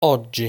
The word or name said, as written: Oggi